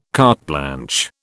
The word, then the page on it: carte blanche: British English